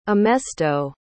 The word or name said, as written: emesto